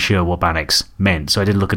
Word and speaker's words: Baaneex: This is Bannocks, I believe. It's spelled B-A-N-E-E-X. And it's Wolof, apparently, which is W-O-L-O-F.